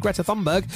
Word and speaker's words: Greta Thunberg: And finally, Greta Thunberg, that's how you correctly pronounce it, Greta Thunberg, is celebrating her 21st birthday today.